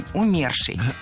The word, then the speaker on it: умерший: В этом слове ударение ставим на второй слог, на корень – умерший.